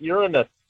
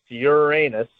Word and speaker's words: Uranus: Yeah, it's not Uranus. That's not how we're saying it. – I know. I'd have to go back. I can't. My brother's no longer alive to ask him. I never heard him call it anything but Uranus his whole life. – It is pronounced Uranus.